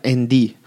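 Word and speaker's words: Endee: Fue fundado en el año 1882 y su nombre proviene del ND Ranch, que se pronuncia Endiy.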